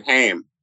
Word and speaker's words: Haim: No, it's Haim. Rhymes with time. – You said Haim.